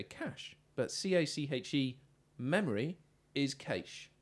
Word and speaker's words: cache: Cash, pronounced cash, according to the OED.